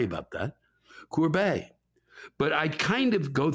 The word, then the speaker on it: Courbet: But where do you get off, Lewis, not knowing that the et or et at the end of the French names is normally pronounced a? Say it 10 times, Lewis. Courbet, Courbet, Courbet, Courbet, Courbet, Courbet, Courbet, Courbet, Courbet, Courbet.